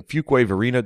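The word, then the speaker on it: Fuquay-Varina: Eddie Eaton announced this week he's a pastor in Fuquay Varina.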